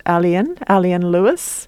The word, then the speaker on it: Elien: Yes, it's by, I think her name is pronounced Alien Lewis. E-L-I-E-N.